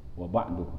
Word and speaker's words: wa ba'du: Adapun cara membacanya yaitu dengan dumah wa ba'du